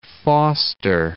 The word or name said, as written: Foster City